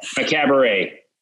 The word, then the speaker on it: Macabre: Is that, help me pronounce it, please. Is it Macabre? No, I want to hear you say it. – Macabre. – Yeah, Macabre.